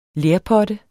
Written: lerpotte

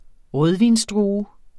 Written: rødvinsdrue

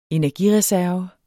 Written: energireserve

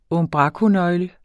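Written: unbrakonøgle

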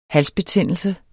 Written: halsbetændelse

